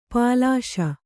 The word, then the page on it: ಪಾಲಿಷ್: pāliṣ